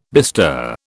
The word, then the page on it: bestir: British English